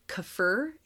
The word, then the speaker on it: kaffir: You can also get, I think it's pronounced kaffir, K-A-F-F-I-R, kaffir, or key lime.